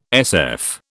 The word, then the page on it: SF: British English